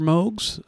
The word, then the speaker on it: Moog: You know, the one M-O-O-G, which most people pronounce Moog, which I pronounced Moog for decades, only to find out it's actually pronounced Moog, as in Robert Moog.